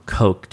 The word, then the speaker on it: Koch: Well, it's K-O-C-H, Koch, or Coke, depending on how you pronounce it.